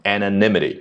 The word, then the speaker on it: anonymity: 与此同时，还有什么额外的情况呢？With some users rattled by the perceived reduction in their online anonymity，这个 anonymity 啊对应 anonymous 啊匿名的啊，只不过作为名词形式呢，就是这个人匿名这样的一个情况啊，它的读音稍微啊要注意一下那个重音 anonymity。